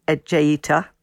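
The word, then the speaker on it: Ejaita: Oh, well, it's just fantastic to have you with us because this beautiful book with exquisite illustrations by Diane Ejeita, if that's the right way to pronounce it. – Well done. You got it right first time.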